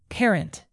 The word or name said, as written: parent